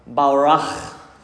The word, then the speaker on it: barak: Our theme in 2021 is going to go back to a Hebrew word, and that Hebrew word is barach. You got to get your throat going, Tyler. Barach.